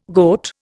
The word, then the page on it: goat: Indian